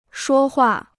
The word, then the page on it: 说话: shuō huà